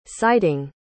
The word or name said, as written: citing